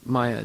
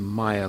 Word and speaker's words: maya: Maya doesn't mean maya like illusions. Maya is the way you pronounce that. It has two long vowels. But when it's just regular short vowel, maya, that means a certain state of consciousness.